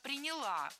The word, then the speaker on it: приняла: Была создана особая комиссия, которая и приняла вот в работе эту систему. – Не приняла, а приняла. Ударение в этом глаголе ставим на окончание.